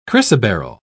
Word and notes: chrysoberyl: British English

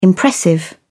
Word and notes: impressive: British English

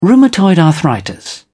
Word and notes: rheumatoid arthritis: British English

